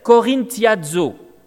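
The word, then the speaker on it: korinthiazo (κορινθιάζω): se prononce en grec « korinthiazo ». Pas besoin de parler le grec pour voir la ressemblance. « Korinthiazo », vivre dans la débauche.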